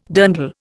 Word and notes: dirndl: Indian

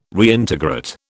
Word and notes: Reintegrate: British English

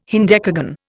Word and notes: hendecagon: Indian

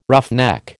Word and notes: roughneck: American English

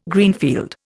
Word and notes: Greenfield: Indian

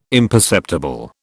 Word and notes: imperceptible: British English

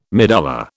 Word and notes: medulla: British English

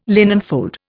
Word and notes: linenfold: Indian

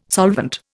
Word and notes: solvent: Indian

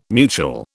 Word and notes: mutual: British English

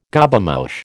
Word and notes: Gobemouche: American English

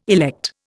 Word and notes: elect: Indian

